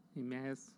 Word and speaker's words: Emmaus: Emmaus, yeah.